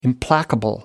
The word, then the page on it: implacable: American English